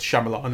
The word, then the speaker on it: Shyamalan: I'm pretty sure it's Shyamalan as we've been saying it.